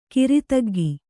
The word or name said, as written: ಕಾರ್ತಿಕಿಕ